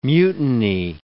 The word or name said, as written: mutiny